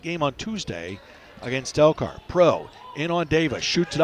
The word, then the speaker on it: Prough: Out of the top for Pro. Spelled P-R-O-U-G-H, but pronounced like P-R-O.